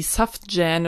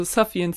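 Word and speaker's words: Sufjan: Und ich habe irgendwie Safjan oder so gesagt, weil da halt ein J drin vorkommt. Und ich war so, das ist ein J, wie wird das ausgesprochen? Also Safian Stevens heißt der.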